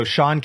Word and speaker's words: Sean: So Sean Cannell rhymes with YouTube channel on Instagram and all the social media platforms. That's S-E-A-N for Sean. And then my last name, Cannell, C-A-N-N-E-L-L.